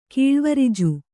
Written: ಕೀಳ್ವರಿಜು

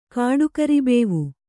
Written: ಕಾಡುಕರಿಬೇವು

